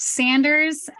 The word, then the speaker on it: Sanders: So it's pronounced Sanders, but I just got divorced and I'll probably change it later, but that's my name.